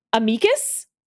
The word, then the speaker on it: amicus: There are two kinds of legal kibitzers. Those who pronounce A-M-I-C-U-S amicus and those who pronounce it amicus.